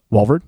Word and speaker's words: Walvoord: Walvoord. – I always wondered how to say his last name. – Yeah. Yeah. – It's spelled funny when you see his name. – W-A-L-V-O-O-R-D.